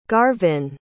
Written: garvin

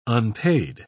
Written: unpaid

